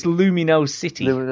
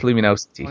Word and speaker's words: Lumino City: It's Lumino City. Luminosity, as Duke said it's pronounced. – Okie dokie. – But it's Lumino City.